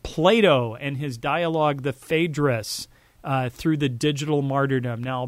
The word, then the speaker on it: Phaedrus: Phaedrus is how you say it in English.